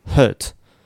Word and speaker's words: hurt: 本当に口を開けずにですね、うーとか、うー、うーみたいな音を言うと、これでイギリスっぽい音になります。 hurt, hurt. 働くだと work。で、アメリカ発音とかだと、舌を丸めるんですね。うー、 er て言います。それで hurt, work で